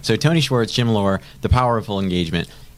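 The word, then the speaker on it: Loehr: I think it's Lair. Well, I listened to it just a second ago on Audible so we could say it right.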